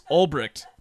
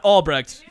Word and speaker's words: Ulbricht: Well, the website's been taken down because this guy, Ross Ulbricht, not Albrecht, it's spelt with a U.